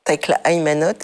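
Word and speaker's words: Takla Haymanot: Takla Emano et Evostateos. – Tekla Aymanot et Eostateos.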